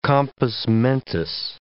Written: compos mentis

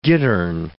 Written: gittern

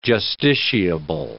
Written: justiciable

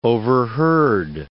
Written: overheard

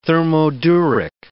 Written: thermoduric

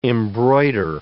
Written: embroider

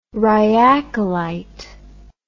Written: rhyacolite

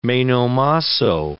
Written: meno mosso

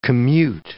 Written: commute

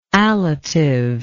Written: allative

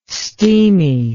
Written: steamy

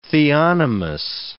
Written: theonomous

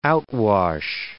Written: outwash

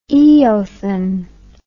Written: eothen

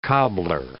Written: cobbler

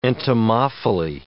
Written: entomophily

